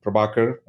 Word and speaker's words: Prabhakar: And my name is Joseph and Prabhakar, and you said it right.